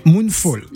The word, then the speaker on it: Moonfall: Alors on va parler de notre film, c'est c'est Moonfall. J'ai bien prononcé. – Non. – Moonfall. – Oui. – En anglais. Moonfall.